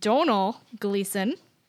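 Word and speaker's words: Domhnall Gleeson: So yes, we learned that this actor's name is pronounced Donal Gleason, who, I was not even close.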